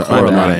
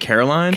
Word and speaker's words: Coraline: Coraline. Am I saying this wrong? – Yes, yeah. – Coraline. – Okay. – It's a Coraline. A. Yes. – Wow. I'm sorry. I was like Caroline.